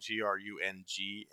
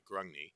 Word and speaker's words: Grungni: It's spelled Grungni, Grungni. Grimnir was a passionate, violent warrior who's glory-driven with fire and aggression.